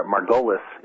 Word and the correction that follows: Margulies: is that how I pronounce his name, pointed out in your program just a couple of weeks ago. – It's actually Margulies. – Margulies.